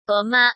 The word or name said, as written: goma